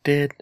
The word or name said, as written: ded